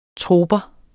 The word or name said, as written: troper